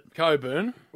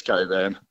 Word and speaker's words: Cockburn: How do you call it? Coburn. – It's Coburn. – What? But it's spelt Cockburn, isn't it?